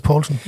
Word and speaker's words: Poulsen: jo, men jeg har hørt noget om, at det skal udtales Poulsen.